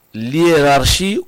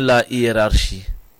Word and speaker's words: hiérarchie: On dit l'hiérarchie ou bien la hiérarchie? – La hiérarchie.